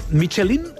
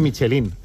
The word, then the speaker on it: Michelin: A ver, aquí en la Península Ibérica hacemos Michelin. – Sí, claro, pero la realidad, tú eres un chef internacional. – Ya, pero ¿tú cómo lo pronuncias? – Me cuesta ser tan tontorraco, ¿no? Dice Michelin. – ¿Michelin? – Yo digo Michelin.